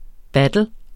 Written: battle

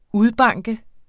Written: udbanke

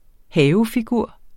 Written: havefigur

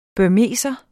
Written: burmeser

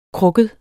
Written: krukket

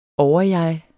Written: overjeg

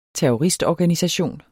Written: terroristorganisation

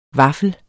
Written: VAFFEL

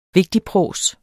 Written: vigtigprås

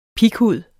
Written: PIGHUD

